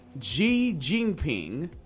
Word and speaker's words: Xi Jinping: Xi Jinping.